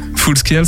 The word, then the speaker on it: Full Scale: bonsoir Full Scale, c'est bon la prononciation parce que y a beaucoup de critiques sur.